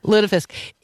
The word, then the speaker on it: lutefisk: Lutefisk. Did I get it right this year? – Yes, Lutefisk. – Lutefisk.